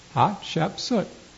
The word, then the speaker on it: Hatshepsut: The perhaps most famous queen in Egypt was Hatshepsut. Okay, let's learn to pronounce that. And then afterwards you can forget it. First, can you say hat? Hat, okay. And thou shep, like shepherd. Soot, like carbon. Hatshepsut.